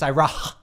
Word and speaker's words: Iroh: You're making it sound like the H is silent. It's Iroh.